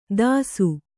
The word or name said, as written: ತಾಸು